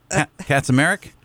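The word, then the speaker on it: Kaczmarek: Katzmarek. Katzmarek? – Bless you.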